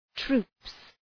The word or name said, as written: troops